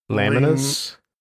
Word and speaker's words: Laminas: Yeah, and they're actually spinning it off to it's called I don't even know how to pronounce this. – Laminus. – Laminus, is that it? Laminus.